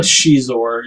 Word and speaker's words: Xizor: Yeah, it said Xizor is how it's pronounced. That's scissors.